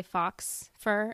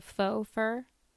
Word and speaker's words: faux: Like, I have heard, I have some friends that will say fox fur instead of faux fur. because of how it's spelled, Fox. They'll call it Fox.